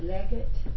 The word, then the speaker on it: Legate: Some people call it Legate, but it's spelled L-E. In capital G A T E, Legate.